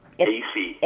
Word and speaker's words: Aiesi: AC. – AC. It's spelled A-I-E-S-I, and I'm with the Myrtle Beach Police Department here in South Carolina.